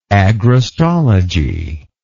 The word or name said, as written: agrostologist